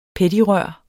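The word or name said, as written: peddigrør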